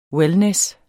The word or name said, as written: wellness